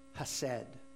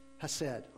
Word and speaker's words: hesed: Well, here, in this expression of the word love, we find a characteristic of God and his love in the translated word hesed, or hesed, however you want to say it. Hesed. I'm going to pronounce it hesed.